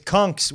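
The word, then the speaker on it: conchs: It's cunks with a hard C.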